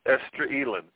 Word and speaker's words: Esdraelon: Did I say it right? Did I get it? – Esdraelon. – Esdraelon. No, I was way off.